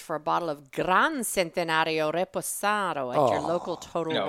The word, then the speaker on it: Centenario: It's Centenario is C-E-N. – That's what I said, like Centenario. It's spelled, you know. – Like century.